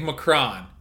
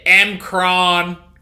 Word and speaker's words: M'Kraan: Wait, wait, is it Macron or Macroon? – It's spelled M apostrophe K R A A N, which you would think Macron, but they say Mcron crystal, like they're from Alabama.